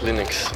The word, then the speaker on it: Linux: As Linux.